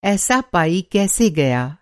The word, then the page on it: पाई: پائی